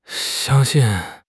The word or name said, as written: xiang1 xin4